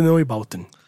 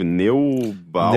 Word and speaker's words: Neubauten: Na verdade, o nome completo da banda é Einstürzen der Neubauten.